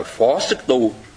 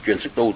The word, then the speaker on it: phó sức tu: Hương truyền sức tu, đúng ra cái này chữ nho của nó là phó sức tu. Phó sức tu chứ không phải truyền sức tu, tại sao đọc là truyền.